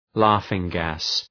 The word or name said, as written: laughing-gas